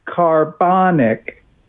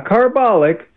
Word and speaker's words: carbonic: Thanks to the person who texted me, I did pronounce it incorrectly. The CO2 that gets dissolved in rainwater creates carbonic, not carbolic, but carbonic acid.